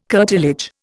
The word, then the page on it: curtilage: Indian